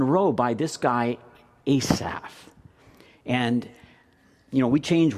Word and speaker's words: Asaph: David wrote Psalm 37, and a guy named Asaph, now pronounce it correctly, it's not Asap, but Asaph.